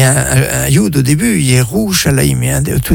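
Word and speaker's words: Yerushalayim (ירושלים): Et c'est très intéressant, parce que dans la traduction de Jérusalem, dans toutes les langues en général, on parle de Jérusalem, Jérusalem, eh bien c'est prononcé sans le Yud. Alors qu'en hébreu, c'est Yerushalayim,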